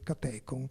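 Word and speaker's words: katechon: un catecon, anzi catecon, aspetta che l'accento è giusto, è catecon, che è ciò che trattiene l'infinità dell'anticristo.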